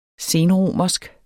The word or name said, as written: senromersk